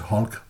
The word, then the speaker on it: Hulk: Og ordet Hulk, eller Hulk, som det hedder på dansk, men det udtales Hulk, det er et gammelt engelsk ord for sådan en stor uformelig klump.